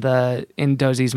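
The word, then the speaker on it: Doose's: And then they're wondering in Dozy's Market. Sometimes. – Let's ask this question. Do you think it's weird that it's spelled like D-O-O-S-E and it's pronounced Dozy?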